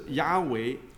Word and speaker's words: Yahweh: So how it's spelled in English is YHWH. – 最接近的发音是 Yahweh. – And the most closely pronounced as Yahweh.